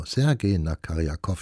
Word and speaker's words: Sergei Nakaryakov: Sie hörten aus einem Konzert oder dem Konzert in S-Dur den ersten Satz und es spielte hier gerade Sergei Nakarik. Na, jetzt wollen die Silben nicht so locker fließen. Also, Sergei Nakaryakov spielte die Trompete, das St. Paul Chamber Orchestra begleitete ihn dabei.